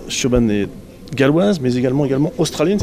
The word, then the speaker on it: Siobhan: Est-ce que j'ai bien prononcé Siobhan Owen? – Siobhan. – Siobhan. – Ouais.